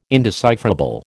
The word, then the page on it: indecipherable: American English